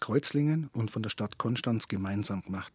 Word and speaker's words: Konstanz: Seenachtsfest in Konstanz, äh, Konstanz, man muss es alemannisch aussprechen, Konstanz, das ist ja Größe am See.